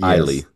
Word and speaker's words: Isley: It's yes, not like you did Island say, where it's yes, Isley. You, no, it's Isley, if you like, say Isley, I will like come ice, to ice, Brazil baby.